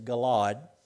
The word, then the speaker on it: Gilead: Now, I'm pronouncing it Gilead. I think the correct is Galad, but we're going to say Gilead.